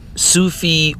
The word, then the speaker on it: Sufi: About Sufi origins of Freemasonry.